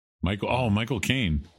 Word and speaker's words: Michael Caine: Mike O'Kane. – Michael Caine. – Yeah. – I let you down. Oh, no. – You know how to pronounce his name by saying my cocaine.